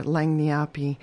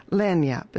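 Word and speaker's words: lagniappe: Langniapi is how I used to say it. That's not how you say it. And they never said anything because they're just so joyful. Lanyap is how you say it.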